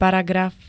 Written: paragraf

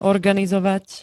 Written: organizovať

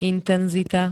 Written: intenzita

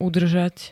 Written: udržať

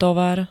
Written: tovar

